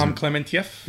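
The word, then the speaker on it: Pom Klementieff: I mean, Palm, I can't pronounce her last name, but the girl plays Palm Clementieff.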